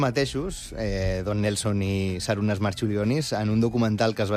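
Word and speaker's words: Marčiulionis: En tot cas, l'escena on Nelson i Marciulionis, no sé si ho he acabat de dir bé. – Jo crec que li diuen Marciulionis, però... Marciulionis. Perquè que aquesta C es pronuncia com una TX.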